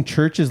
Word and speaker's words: CHVRCHES: And, man, if you haven't seen Churches live, and it's spelled C-H-V-R-C-H-E-S, which is bullshit, but I still enjoy the shit out of these guys because I've seen them live a couple of times at Coachella.